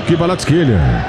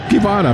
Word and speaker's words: Kvaratskhelia: Gostei da pronúncia aí do Kvaratskhelia. Mas tem gente que prefere falar Kivara apenas.